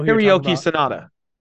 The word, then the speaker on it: Hiroyuki Sanada: I know Hiroyuki Sanada.